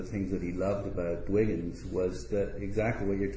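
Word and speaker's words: Dwiggins: I mean, I really like William Addison Dvigins, who I mentioned before, American type designer and book designer, first half of the 20th century. – Dvigins? Yes, pronounced? – Dvigins, yeah.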